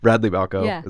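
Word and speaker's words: Radley Balko: Radley Balco is how I'm pronouncing it. I never knew how to pronounce it. – That's right.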